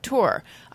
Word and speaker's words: tour: Tour rhymes with your.